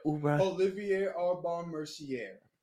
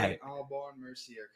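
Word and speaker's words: Olivier Aubin-Mercier: Olivier Aubin Mercier. – I love that name. Olivier. Why? – Olivier Aubin Mercier, because it's French, bro.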